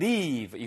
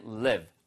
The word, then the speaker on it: live: 이거 길게 발음하시면 leave. 이거는 떠난다는 뜻이고요. You can't live. live처럼 들리면은 바로 그게 살다는 뜻입니다.